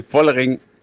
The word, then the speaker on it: Vollering: Vollering. Zwischen F und V, Vollering.